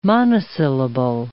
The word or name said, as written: monosyllable